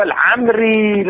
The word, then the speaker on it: العمري: همات عن عيسى بن عبد الله العلوي العمري او العمري، الواو ما مذكوره، طبعا هو العمري لازم يذكرون الواو ولا تلفظ ظاهرا، يعني كانه هكذا العمروي تصير ولكن لا تلفظ،